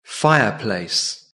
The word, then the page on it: fireplace: British English